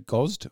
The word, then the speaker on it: Gozd: So the band, I'll spell it, is G-O-Z-D. So Gozd or Gozd.